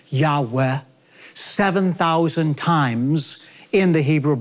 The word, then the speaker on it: Yahweh: When I say God's sacred name, which most Semitic scholars believe is pronounced Abrathi, Yahweh, I say that very reverently.